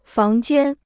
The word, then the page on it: 房间: fáng jiān